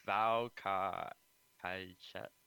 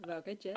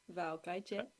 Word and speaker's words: Vào cái chết: Maybe you can say it. Vào Ca Hai Chet. VaoCaiChet. – Vào cái chết. – I'm after. How do you say it? – Vào cái chết.